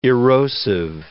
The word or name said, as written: erosive